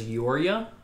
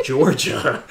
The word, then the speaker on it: Jorja: Just because her name is spelled J-O-R-J-A. And I thought it was Yorja. But they said Georgia.